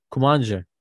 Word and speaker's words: Kumancı: Yalnız bu nasıl okunuyor bu adamın adı ya? – Kumancı. – Aynen aynen.